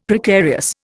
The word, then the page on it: Precarious: Indian